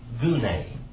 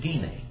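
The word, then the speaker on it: gine (γυνή): Okay, it's, now he would pronounce it, Dieter Bergstrasser would pronounce that, gune. But it's gine. Gine. This is a Y.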